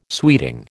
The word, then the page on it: sweeting: American English